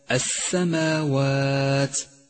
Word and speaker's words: السماوات: سادسا لام التعريف في كلمة السماوات، لام شمسية تكتب ولا تلفظ، ولذلك هي رمادية اللون إشارة من مصحف التجويد إلى عدم لفظها.